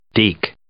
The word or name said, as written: deke